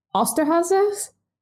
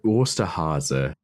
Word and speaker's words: Osterhase: Okay, something I can say is Osterhase. – Osterhase? I used to take German. This is embarrassing. – Yeah, there you go.